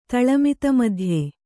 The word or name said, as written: ತಳಮಿತಮಧ್ಯೆ